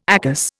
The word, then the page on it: ACAS: Indian